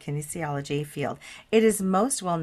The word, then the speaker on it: kinesiology: Kinesiology. Am I saying that right? – Applied kinesiology. Yes. Yes.